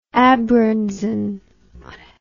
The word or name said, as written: abgrenzung